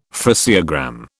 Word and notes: phraseogram: British English

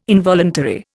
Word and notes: involuntary: Indian

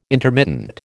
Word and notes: intermittent: American English

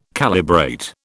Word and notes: calibrate: British English

